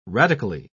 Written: radically

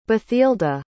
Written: bathilda

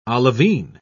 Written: olivine

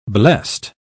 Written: blest

